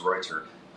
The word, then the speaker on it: Reuter: So Marcus, and it's I can't pronounce it the German way, but I say Reuter. He says even more Germanic sounding. So it's Reuter.